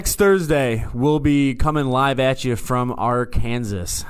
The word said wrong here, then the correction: Arkansas: Well, I don't like how you said Arkansas. – Oh, I'm sorry. One. Did I pronounce it wrong? – You best show respect. – No, I'm sorry. Arkansas. I'll be in Arkansas.